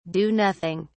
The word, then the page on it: do nothing: British English